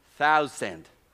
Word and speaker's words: thousand: And Mel was around 30 minutes trying to teach me how to pronounce thousand. TH. So this is why. And he say, thou. And outside was the sand. Sand. He say, thou, sand. Yeah.